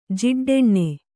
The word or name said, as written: ಜಿಡ್ಡೆಣ್ಣೆ